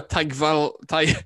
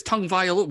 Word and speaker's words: Tagovailoa: And then number one, it's Tua Tagovailoa, because where's the N? Everyone says Tagovailoa. Where's the N in there? There's no fucking N.